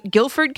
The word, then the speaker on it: Guilford: Is it Guilford, Connecticut? Is that how I say it right? – Yes, it is.